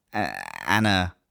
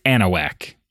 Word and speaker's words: Anahuac: So this is, you know, this is Anahuac. – Anahuac, yeah. Anahuac, yeah. No, it's not. It's not that at all. It's Anahuac, actually. – Whack spelled H-U-A-C, you know, like the classic whack.